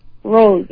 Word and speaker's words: Rose: Rose. R-O-S-E.